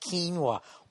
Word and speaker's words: quinoa: I guess quinoa, even though it's spelt O-A, is not the way to say it. Some fucking asshole on Twitter is giving me shit. You're driving me crazy. It's pronounced quinoa.